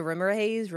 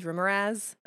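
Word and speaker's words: Remorhaz: It is spelled R-E-M-O-R, haze, so Rimmer Haze, Rimmeraz, I've also heard it pronounced as. Either way, it's probably fine.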